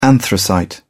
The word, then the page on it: anthracite: British English